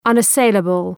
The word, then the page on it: unassailable: ,ʌnə’seıləbəl